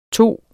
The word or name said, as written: tage